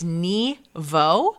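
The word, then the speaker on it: Nghi Vo: So I believe it's Ni Vo. – Okay. – But the author's first name is spelled N-G-H-I. – Okay. – And the last name is V-O.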